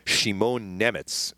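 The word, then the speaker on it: Šimon Nemec: Šimon Nemets is how you say his name.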